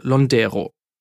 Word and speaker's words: Londero: In Italien betont man seinen Nachnamen übrigens auf der ersten Silbe. Weil viele Menschen in Deutschland damit aber Schwierigkeiten haben, sagt Giuseppe selbst mittlerweile Londero, also mit Betonung auf der zweiten Silbe.